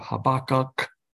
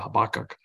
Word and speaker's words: Habakkuk: Okay, so Habakkuk chapter 3, and it starts out with a prayer of Habakkuk. That's the Hebrew pronunciation. I just kind of use the Habakkuk.